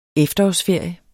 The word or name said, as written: efterårsferie